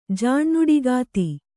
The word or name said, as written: ಜಾಣ್ನುಡಿಗಾತಿ